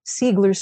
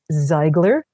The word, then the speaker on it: Seigler: And for those of you of German extraction, I know it's supposed to be pronounced Ziegler, but out here, this is the way they pronounce it.